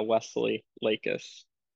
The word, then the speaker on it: Wesley Lakis: It's Wesley Lakis.